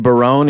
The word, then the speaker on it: Barone: If I'm going all in on the pronunciations, I'll say David Barone, even though it looks like Baron, and sure.